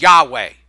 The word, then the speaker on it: Yahweh: That's how they pronounced it, was Yahweh.